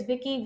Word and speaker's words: Vicky: Yeah, my Twitter is Vicky, V-I-C-K-Y. Arnstein is A-R-N-S-T-E-I-N.